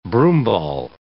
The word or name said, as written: broomball